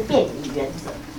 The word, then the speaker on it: 便宜原则: 所以这个你要把它念成便宜，然后不是便宜，然后是便宜原则。